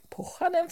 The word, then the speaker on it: Pohjanen: Då kunde jag fråga en av våra lyssnare som skriver på vår Facebook-sida, Facebook-sida, hur vill författaren att namnet ska uttalas uppe i norr där. – Mm. – Och då sa han att det är som jag säger, Pohjanen fortsätter jag, men att vissa säger Pohjanen.